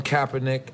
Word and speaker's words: Kaepernick: So Colin Kaepernick, I gotta.